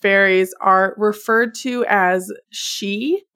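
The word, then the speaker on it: sídhe: And these are like not spelled at all how they're pronounced. I did some very careful like looking up the pronunciation to figure this out. But she or fairy.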